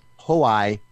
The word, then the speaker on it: Hoai: This is the Trap Future Bass Hoai remix. That's H O A I. – I wondered how you pronounce that.